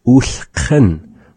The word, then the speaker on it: ulhqn: And so that's having our kha, the q. The word is spelled with our nuchalk alphabet. U, sa, kha, na. That is U, L, H, Q, N.